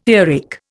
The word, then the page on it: earache: Indian